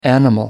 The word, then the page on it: animal: American English